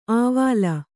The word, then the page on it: ಆವಾಲ: āvāla; kannada